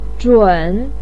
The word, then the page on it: zhun: 3